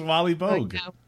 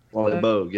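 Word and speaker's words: Wally Boag: It rhymes with Polly Flogue. – Oh, yeah. – Is Wally Bogue. – Wally Bogue, yeah.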